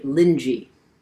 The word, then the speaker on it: Linji: So in this story, the teacher or master who is addressing his monks is named Linji. That's how it's pronounced in Chinese.